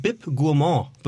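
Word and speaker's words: Bib Gourmand: Nichtsdestotrotz hat deine gute Botschaft einen BIP Gourmand bekommen.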